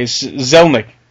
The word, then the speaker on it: Zelnick: Am I pronouncing that right? – Zednik or something? – You've got Zelnick here. – Do I? Okay. Zelnick. Strauss Zelnick.